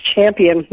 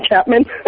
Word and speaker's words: Champion: My name is Champion, not Chapman. – Champion? I kept putting Champion. The H in there like an idiot. – Yeah, you called me Chapman.